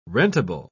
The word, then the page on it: rentable: British English